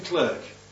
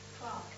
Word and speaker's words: clerk: Anyway, the city clerk, or do you pronounce him clerk? – Clark.